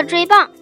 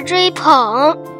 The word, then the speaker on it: 追捧: 尼尔狗的纸钞上印有猫的图案，因为稀有可爱而备受游客追棒啊追捧。哎呀，我咋读成棒了呢？